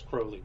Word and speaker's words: Crowley: The proper pronunciation is Crowley, but...